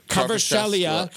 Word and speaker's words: Kvaratskhelia: Kvaratskhelia.